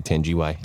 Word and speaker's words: Tangiwai: Tangiwai or Tangiwai? – Yeah, I'd say Tangiwai.